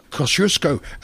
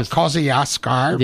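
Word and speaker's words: Kościuszko: I was listening to something on radio from the US or somewhere, and they were talking about Kosciuszko, and they used that Polish pronunciation, not Kosciuszko.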